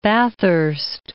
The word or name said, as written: Bathurst Island